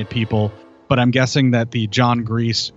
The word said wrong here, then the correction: Grise: inside of the Wolfman, or Grise, I'm sorry, I always mispronounce his name,